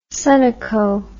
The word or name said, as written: Sunoco